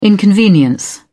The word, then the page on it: inconvenience: British English